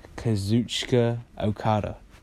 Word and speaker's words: Kazuchika Okada: Switchblade, versus Kazuchika Okada.